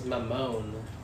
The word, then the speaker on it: Mamone: I was like, Hey, how do you pronounce your na- that that name? He's like, Oh, it's Mamon.